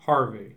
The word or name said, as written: Harvey